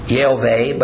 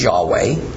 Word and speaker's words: Yahweh: and which I pronounce Yehovah, but it's more widely pronounced as Yahweh.